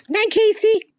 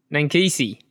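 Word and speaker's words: Ninkasi: Well, how do you pronounce it? – Nankisi. – Nankisi. Yeah, right.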